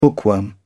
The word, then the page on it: bookworm: British English